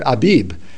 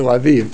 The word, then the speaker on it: Aviv: They wanted to name it Abib, and I said, no, that's not how you pronounce it, and so they changed it to Aviv. – I've often heard Laura Wood say Abib. – Because in the English Bibles they've gotten the pronunciation wrong.